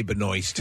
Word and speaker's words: Benoist: Melissa Benoist. Is that how you say her name? – That's how I say it. I'm going to say Benoist.